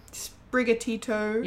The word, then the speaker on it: Sprigatito: Um, so Sp- Sprigatito.